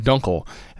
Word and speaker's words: Dunkel: No, you got the Dunkel part right.